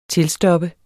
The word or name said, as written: tilstoppe